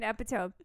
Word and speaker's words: epitome: Like, it's an epitome, not epitaph. Epitome, pronounced epitome.